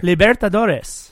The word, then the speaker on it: Libertadores: 2016, he wins the Super Copa MX, qualifying for, I'm going to butcher this, qualifying for the Libertad, uh, Scarf, Liber- help me out here. Libertadores. – Thank you.